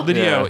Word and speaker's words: L'Idiot: Patrick Stewart was the maitre d' at the restaurant, which, how did they pronounce it? – Lidio, yeah, which is L'Idiot, is their French restaurant that they go to.